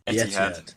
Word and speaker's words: Etihad: And if you remember, can you remember, was it at Craven Cottage or was it at the Etihad? Am I saying that right? – Etihad.